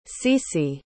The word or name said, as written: cece